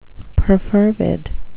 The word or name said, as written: perfervid